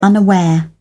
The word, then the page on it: unaware: British English